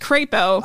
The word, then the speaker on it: Crapo: We have a representative, no, it's a senator now, I believe, Mike Crapo Hell yeah. That's a tough life. – supposed to be tail. It's supposed to be pronounced crepo.